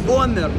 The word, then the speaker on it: Бумер: А, ну, скажи, а, как правильно теперь это произносится? Бомер. Бомер, да. Бомер. А ты как сказал? Я сказал Бомер.